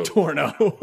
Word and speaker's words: Toronto: You gotta say Toronto. – I'm gonna go to Toronto. – It's two syllables, barely. – Toronto. – Not Toronto. – Toronto. – It is Toronto. – Torno.